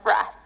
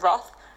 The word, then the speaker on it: wrath: Just wanted to let you know that I pronounced that word as wrath. So make of that what you will, but I have been told in the past to pronounce it Roth, but I'm pretty sure it's just another of those geographical difference words.